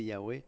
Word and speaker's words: Yahweh: On ne sait pas trop comment ça se prononce. On a une bonne idée que c'est Yahweh.